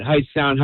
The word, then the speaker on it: Hightstown: So I went to high school at High Sound High School. Mm-hmm. It's in Mercer County, so it's spelled H-I-G-H-T-S-T-O-W-N.